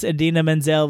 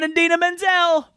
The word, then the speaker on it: Idina Menzel: I was really worried I was going to pronounce Idina Menzel like John Travolta. I beat him. I may mispronounce lots of things on the show, but not Idina Menzel.